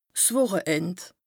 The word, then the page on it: 's Wùcheend: Bas-Rhin; Strasbourg